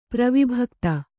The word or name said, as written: ಪ್ರವಿಭಕ್ತ